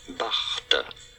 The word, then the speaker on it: Barthes: For reference, in France, in French, it is said as Roland Barthes.